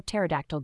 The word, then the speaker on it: pterodactyl: Why don't you ever hear a pterodactyl go to the bathroom? Because the P is silent.